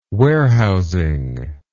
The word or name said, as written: warehousing